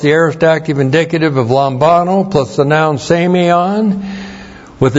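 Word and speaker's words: lambano (λαμβάνω): And then we have lambano. L-A-M-B-A-N, long O, lambano.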